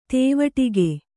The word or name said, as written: ದೀವಟ್ಟಿಗ